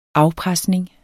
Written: afpresning

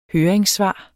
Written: høringssvar

